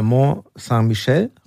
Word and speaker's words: Mont Saint-Michel: Und auch toll finde ich einige Zeilen in äh, Mont Saint-Michel, richtig ausgesprochen? – Ja.